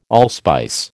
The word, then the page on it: allspice: American English